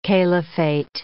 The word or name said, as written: caliphate